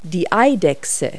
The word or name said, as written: lizard